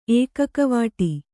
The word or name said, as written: ಏಕಕವಾಟಿ